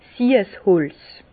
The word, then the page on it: 's Siesholz: Bas Rhin; Herrlisheim